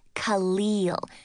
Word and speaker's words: Khalil: She pronounced it Khalil, with the emphasis on the leal.